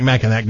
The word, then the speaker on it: Mackinac: Yeah, it looks like Mackinac, but it's pronounced Mackinac Island.